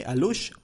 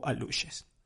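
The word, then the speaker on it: alux: me comentó que se pronuncian así, alush o aluches.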